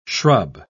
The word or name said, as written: shrub